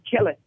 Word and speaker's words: Kelly: My name is Brian Kelly. Brian Kelly. Is how it's pronounced. It's how my grandpappy pronounced it and how my grandmammy pronounced it, and it's been Kelly for a long time.